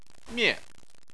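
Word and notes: mie4: tone 4

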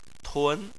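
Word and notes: tun: tone 2

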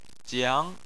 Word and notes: jiang: 2

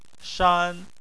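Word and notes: shan: tone 1

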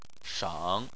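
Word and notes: sheng: tone 3

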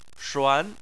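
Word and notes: shuan2: tone 2

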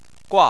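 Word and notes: gua: tone 4

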